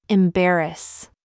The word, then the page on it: BOB: American English